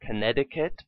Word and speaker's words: Connecticut: The U.S. state of Connecticut and the river of the same name also have a silent C before T.